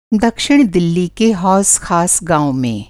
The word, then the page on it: हौज़ ख़ास: hauz khas